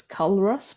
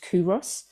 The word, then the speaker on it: Culross: The place is, it's spelt like it's Culross, but it's pronounced Kuros, and it's beautiful.